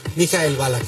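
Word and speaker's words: Michael Ballack: ¿Cómo se dice bien? ¿Mijael Balak? – Mijael Balak, sí.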